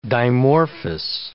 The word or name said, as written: dimorphous